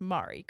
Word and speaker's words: Mare: And you ask how to pronounce my name and it is, okay, and they say M-A-R-E. So you would assume that's Mari, correct? – Yes. – Okay, Mari. Yes.